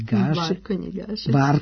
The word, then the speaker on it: Gašić: Prekrstili su me jedno 100 puta, ako ne i više, ali na Novom Zelandu i ne pokušavam tamo, čak i ono Gašić što bi bilo lako izgovoriti, ovde je nešto Gesik ili već kako ispadne.